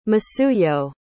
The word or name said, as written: masuyo